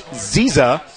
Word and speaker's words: Nziza: Josh Ziza, silent N, by the way. – Okay. Good to know. – Yes, that's exactly right. It's spelled N-Z-I-Z-A.